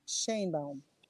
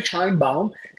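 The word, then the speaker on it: Sheinbaum: Se debe pronunciar Scheinbaum, pero es Scheinbaum en español y pues así lo pronunciamos.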